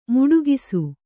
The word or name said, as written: ಮೊಟಕಿಸು